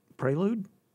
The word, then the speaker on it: prelude: It describes an introductory performance, and I'm going to spell it P-R-E-L-U-D-E. – Prelude? – There's no prey in prelude. See, I can't even say it. Prelude. P-R-E-L-Y-O-O-D.